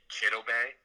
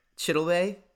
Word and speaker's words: Chidobe: Say it with me. Chidobe. – Chidobe.